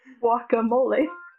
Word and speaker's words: guacamole: What's that avocado thing that you use with chips? Glacome? – Guacamole? – Yeah, glacome, right? – Guacamole. – See, I keep saying it wrong.